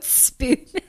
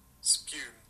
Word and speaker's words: spume: S-P-U-M-E.